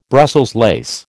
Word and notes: Brussels lace: American English

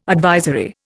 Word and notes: advisory: Indian

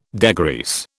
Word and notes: degrease: British English

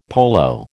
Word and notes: polo: American English

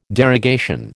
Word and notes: derogation: American English

